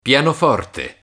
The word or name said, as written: pianoforte